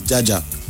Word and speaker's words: Jaja: My social media na just na come na TZ Jaja. – Okay. – TZ Jaja is spelled TZY.